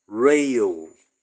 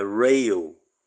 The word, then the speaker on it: Ryle: É John Charles Rayle, e não Riley, como eu estava pronunciando, tá bem? Então a pronúncia mais próxima ali do ambiente britânico é Rayle.